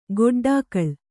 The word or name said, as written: ಗೊಡ್ಡಾಕಳು